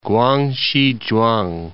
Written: Kwangsi Chuang

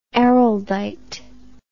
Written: Araldite

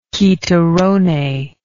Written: chitarrone

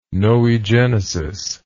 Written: noegenesis

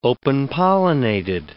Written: open-pollinated

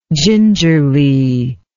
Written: gingerliness

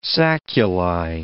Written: sacculi

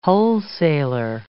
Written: wholesaler

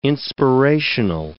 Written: inspirational

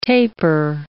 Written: taper

